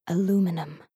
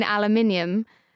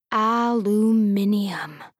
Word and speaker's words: aluminium: "Like aluminum." – "You mean aluminium? – Ah, there it is." He cupped his ears in pleasure. "That extra syllable, aluminium, it undoes me."